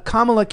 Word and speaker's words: Kamala: Kamala can't fill half a high school gym.